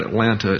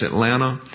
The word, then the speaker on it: Atlanta: That's how you say it. It's not Atlanta, it's Atlanta. And she had this chauffeur down there in Atlanta.